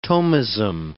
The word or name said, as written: Thomism